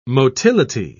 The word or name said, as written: motility